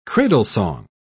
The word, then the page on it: cradlesong: British English